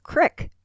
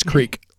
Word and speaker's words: creek: But if I hadn't have taken all those years of training, I would say, you know that crick rhymes with pick. – Yes, but it doesn't. It's creek.